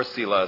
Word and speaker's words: Selah: It has been suggested by many that wherever you see this word Selah, or Selah as I sometimes pronounce it, that it means stop.